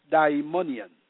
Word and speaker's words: daimonion: There are two words in the Greek language that are used in the Bible for demons or devils, and they are daimonion, pronounced daimonion, which means a demonic being.